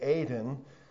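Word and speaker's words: Aden (עֵדֶן): The place was called Eden. Or to pronounce it correctly with the Hebrew word, it would be Aden.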